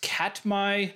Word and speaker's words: Katmai: What I didn't know is that both of them take place in, I think it's pronounced Katmai National Park.